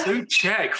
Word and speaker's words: Souček: Thomas Souček. Is that how you say it? Yeah. – Souček. Fuck me. – Souček. – Check. Yep.